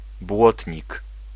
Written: fender

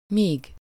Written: where

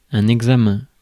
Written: examen

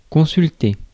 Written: consult

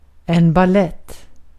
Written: balett

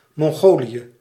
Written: Mongolia

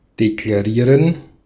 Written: declare

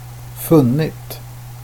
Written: funnit

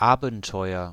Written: adventure

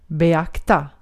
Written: beakta

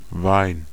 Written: Wein

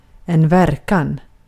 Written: verkan

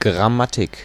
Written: grammar

